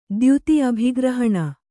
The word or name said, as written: ದ್ಯುತಿ ಅಭಿಗ್ರಹಣ